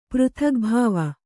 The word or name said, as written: ಪೃಥಗ್ಭಾವ